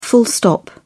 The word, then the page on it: full stop: British English